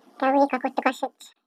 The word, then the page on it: kaawiyihkwaakuhtikwaashich: Chisasibi